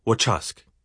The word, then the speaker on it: wachusk: Examples are wachusk, muskrat, which would rhyme with the tusk, and metas, pants, which should rhyme with a pass.